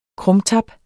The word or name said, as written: krumtap